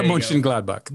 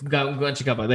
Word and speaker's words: Mönchengladbach: In Manchester City taking on Borussia Mönchengladbach. I'm going to butcher that. – Borussia Mönchengladbach. – Mönchengladbach. There you go.